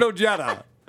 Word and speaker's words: Ojeda: Richard Ojeda is how to pronounce that.